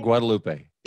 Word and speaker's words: Guadalupe: It was the Lady of Guadalupe? Or I can't say it right. – Our Lady of Guadalupe.